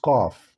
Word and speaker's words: Qaf: Actually, letter C in Arabic is pronounced Qe, therefore the full name of this letter in Arabic is Qaf.